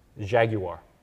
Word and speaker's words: Jaguar: Pronouncing it right. – Jaguar. – There you go. Mm-hmm. – Yeah, more syllables, better.